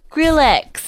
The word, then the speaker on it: Skrillex: You spelled his name wrong. – I did. – You purposely did it. – I put a hyphen in there Skrillex. – So I didn't get it wrong. – No, it's Skrillex.